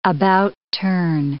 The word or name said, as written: about-turn